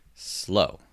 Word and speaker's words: sloe: Wait, how do you spell it? – SLOE. – Yeah, it's got to be slow, right? Let's look it up. Slow. Yes. It's pronounced slow.